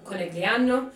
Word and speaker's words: Conegliano: there is one town called Conegliano. We all know that that's how it's pronounced.